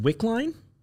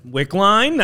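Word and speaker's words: Wicklein: So, by the way, I'll just say for the audience's sake, even though you could see it on screen earlier, I believe that's probably pronounced Wickline. – Yeah. – Wickline.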